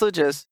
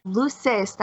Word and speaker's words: luces: Said it wrong. – How do you say? – Luces, not luces. – What do you say? – Apagando las luces. – Luces. There we go.